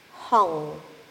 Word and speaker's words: Hong: And a mantra, inhaling, Hong. It is spelled H-O-N-G. Exhaling, Saw. S-A-U.